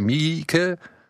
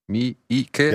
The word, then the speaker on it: Miike: weil ich kannte nur das Remake von Takeshi Miike. Miike. Ja, das ist richtig. Ja? Es tut mir leid, ich habe es immer falsch ausgesprochen.